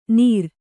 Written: ನೀರ

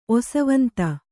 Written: ಒಸವಂತ

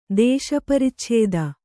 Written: ದೇಶಪರಿಚ್ಛೇದ

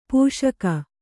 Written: ಪೋಷಕ

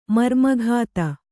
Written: ಮರ್ಮಘಾತ

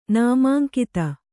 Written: ನಾಮಾಂಕಿತ

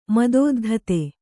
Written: ಮದೋದ್ಧತ

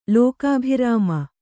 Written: ಲೋಕಾಭಿರಾಮ

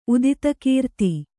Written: ಉದಿತಕೀರ್ತಿ

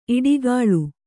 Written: ಈಟಿಗಾಳ

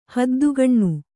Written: ಹದ್ದುಗಣ್ಣು

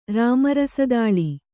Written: ರಾಮರಸದಾಳಿ

